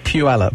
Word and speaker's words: Puyallup: How do you say it? – Puyallup. – Puyallup. That's why I love the way you said it just a few moments ago. You said, hey, Puyallup.